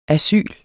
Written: ASYLETS

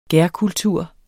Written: gærkultur

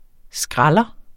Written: skralder

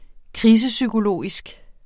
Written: krisepsykologisk

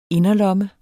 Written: inderlomme

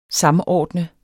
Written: SAMORDNET

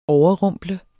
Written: overrumple

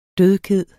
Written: dødkede

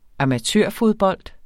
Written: amatørfodbold

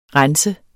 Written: renser

